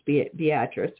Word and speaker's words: Beatrice: Now, it is located in Beatrice, Nebraska, and it is pronounced Beatrice, which is actually the same way my great-great-aunt's name is pronounced, so I feel a kinship for it right there.